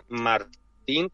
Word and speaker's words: Martín: De hecho, eh, le obligaba a los americanos a que mm, pronunciasen su nombre como si se escribiese Martín con dos es, porque las dos es se pronuncia como una I.